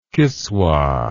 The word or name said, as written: kiswah